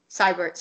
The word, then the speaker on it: Siebert: Siebert spelled S-I-E-B-E-R-T. Siebert, sorry, I pronounced it wrong.